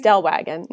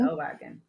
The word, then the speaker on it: Stellwagen: At the, I'm going to butcher the name, Solgen Bank National Marine Sanctuary? – Stellwagen. – Mm-hmm. Stellwagen.